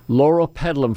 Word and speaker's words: Loropetalum: Loropetalum, for those who didn't quite get all the syllables there, L-O-R-O-P-E-T-A-L-U-M, Loropetalum.